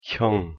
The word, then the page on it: 형: Korean